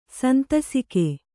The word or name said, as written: ಸಂತಸಿಕೆ